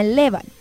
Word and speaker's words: Laban: Se llama Levan. Levan se escribe Laban.